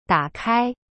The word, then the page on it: 打开: da kai